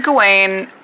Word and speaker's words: Gawain: How do you say it? Gawain? – Oh, okay, so I've heard Gawain. It's more like Gawain in the movie.